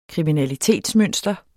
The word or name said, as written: kriminalitetsmønster